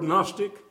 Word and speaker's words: gnostic: The word Gnostic comes from it is spelled G-N-O-S-T-I-C. It comes from the Greek word gnosis, G-N-O-S-I-S.